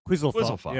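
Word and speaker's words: quizalofop: Quizalofa? How do you say that? – Quizalfop? Yeah, Quizalfop. Yeah.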